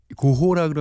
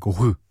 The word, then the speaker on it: 고흐: 그게 고호라 그러면은 좀 옛날 발음이고 빈센트 반 고흐 예.